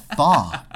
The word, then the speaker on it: pho: Actually, it's pronounced "fa," and since I know that's how you pronounce it, I'm wildly intelligent.